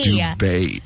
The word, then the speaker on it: Dubai: Dubai? Not Dubai. – No. – I would say Dubai. – It's Dubai. – Dubai. – Dubai is the correct pronunciation.